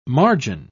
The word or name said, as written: margin